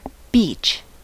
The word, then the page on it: beech: American English